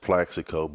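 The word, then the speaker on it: Plaxico: Yeah, it's the craziest thing because my name is spelled with an A, and for some strange reason since I've been, since I can remember, everybody just calls me Plex. It's the craziest thing, but, you know, it's not wrong if you call me Plex, but if you look at it spelled on paper, it's pronounced Plexico, but everybody just calls me Plex, and I'm cool with that.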